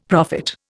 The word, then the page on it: prophet: Indian